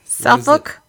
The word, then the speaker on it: Suffolk: Okay, the operator says, this is Suffolk. – Operator. – How do you say it? Suffolk. It's S-U-F-F-O-L-K.